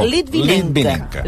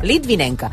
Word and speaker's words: Litvinenko: Era la manera correcta, de pronunciar-ho en català. Era Litvinenko. Litvinenko. Però anem al tema important.